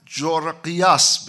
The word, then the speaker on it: گرگیاس: قورس گرگیاس یا جرقیاس هر کدوم که آقایون راحت بودن همونو بگن گرگیاسه بهتره